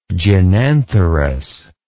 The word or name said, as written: gynantherous